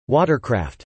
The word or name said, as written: watercraft